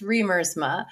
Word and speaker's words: Riemersma: That's what it looks like, how it's spelled, but it's pronounced, yeah. It's pronounced Riemersma. It's Dutch.